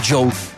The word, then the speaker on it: DJO: Oh, La canción se llama End of Beginning, el artista es DJO, DJO se pronuncia Joe, porque es Joe Carey, el actor de Stranger Things. No es que sea un actor que hace música, él tiene una carrera en la música y ha publicado ya 2 álbumes de estudio, si bien recuerdo. Pasen, lo escuchan, y como les digo, se escribe DJO, pero se dice Joe.